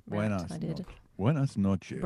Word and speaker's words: buenas noches: Well, I was saying buenos nachos wrong. It's buenos nachos. People were saying, you said it wrong, Rebecca. So did Stereo, the band. – Yeah, because it's buenas noches.